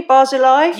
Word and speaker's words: Barzilai: Did I say that properly, Barzilai? – You did.